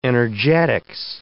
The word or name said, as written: energetics